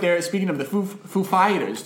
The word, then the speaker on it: Foo Fighters: When Chris Walken was hosting SNL with the Foo Fighters play, he asked the Foo Fighters, what syllable do I stress for Foo Fighters? The first or the second? They were like, definitely the second.